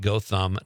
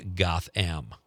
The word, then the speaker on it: Gotham: It's pronounced Gotham, not Gotham. Boy, you really riled some people up tonight. – Gotham. I'll never pronounce it the other way now. – Monica from Mount Horeb says Gotham is kind of like, I was kind of by Richland Center, but I believe they say it with a hard O. Go Tham.